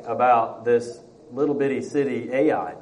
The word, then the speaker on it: Ai: The thing about Ai that I love about it is we call it Ai and it's spelled A-I.